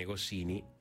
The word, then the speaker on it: Goscinny: Y proyecciones de películas como Asterix y Obelix, La misión Cleopatra, en conmemoración por el centenario del nacimiento de René Goscinny, el guionista de Asterix, porque tiene el acento en la E.